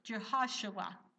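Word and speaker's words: Jehoshua (יְהוֹשֻׁעַ): He said, the name of the child shall be, and it's actually pronounced like this, Jehoshua in the Hebrew.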